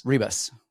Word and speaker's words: Ribas: Like, I guess, do we say Ribas? Because that's how they say it in Portuguese, but yeah. we would say Ribas. – Ribas.